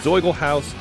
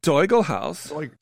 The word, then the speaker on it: Zoiglhaus: It's the Z would be more like a Zoigel House. – Zoigel House.